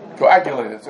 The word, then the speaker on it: coagulated: Now, coming back to the discuss, let's say this mashka over here is something that's cadulated. Basically, mayonnaise. Something, I said that correctly? How do you say it? Coagulated.